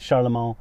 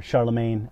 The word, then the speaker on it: Charlemagne: And finally, we came out of the Dark Ages into the Middle Ages, emerging with, you know, the Charlemagne, or Charlemagne, as it's often pronounced in English.